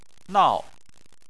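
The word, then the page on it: nao: tone 4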